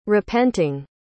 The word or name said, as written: repenting